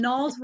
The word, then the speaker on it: Nalle: But anyway, and then Nall's rhymes with small and tall.